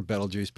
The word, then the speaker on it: Betelgeuse: Now that Betelgeuse, and yes, you can say Betelgeuse or Betelgeuse. Betelgeuse is slightly preferred.